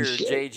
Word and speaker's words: Uche: Josh Uche, he's in his third year, Uche.